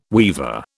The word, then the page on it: weever: British English